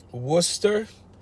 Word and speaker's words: Worcester: If you get a chance, if you like just driving around through old cities, Worcester, that's how they pronounce it in Massachusetts.